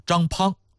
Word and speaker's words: 张滂: 只是有那个盐铁转运使张滂，滂沱大雨的滂，有朋友说那不是滂沱大雨吗？没有那个滂的读音啊，这字儿只有一个读音，就念滂。